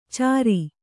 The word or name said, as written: ಚರ